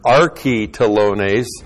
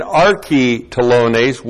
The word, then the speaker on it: archi-telones: An archi-telones A-R-C-H-I-T-E-L, long O-N, long E-S. An archi-telones was a chief tax collector.